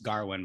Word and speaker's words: Gawain: it's pronounced Garwin, but it's spelled like Gawain,